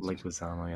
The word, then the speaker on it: Leguizamo: John Leguizamo, I think it's pronounced. – Leguizamo, yes.